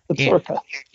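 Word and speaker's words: Absaroka: The Absorca Mountains? Am I saying that right? – Yes, Absorca.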